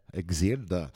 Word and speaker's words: Exiled: Hein, ça se prononce comme ça ? Avec un ED, je sais jamais. – Exiled. – Voilà,